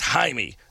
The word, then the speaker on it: Jaime: Can you please play Texas Flood for Jamie? Oh, sorry. It's pronounced Jaime. That's right. It's the Spanish.